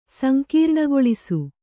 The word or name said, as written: ಸಂಕೀರ್ಣಗೊಳಿಸು